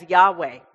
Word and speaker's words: Yahweh: The Tetragrammaton, or Yod-Heh-Vav-Heh as it's known, we pronounce as Yahweh, right? No one really knows how to pronounce it.